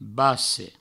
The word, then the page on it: basse: Provençal